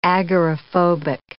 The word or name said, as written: agoraphobic